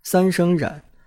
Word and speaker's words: 冉: 最后补充一下，冉阿让这个名字正确读音应该是冉阿让，三声冉，但我很多年来一直都叫冉阿让的，这时候要改觉得有点别扭，所以如果有中学生看我的视频，记住这个字念冉，三声，没有二声的读音。